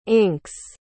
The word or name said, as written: inks